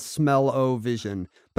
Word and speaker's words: Smell-O-Vision: It was styled Smell-O-Vision, but it was clearly a pun on television. So I don't know if we're wrong to pronounce it to rhyme with television, like Smell-O-Vision.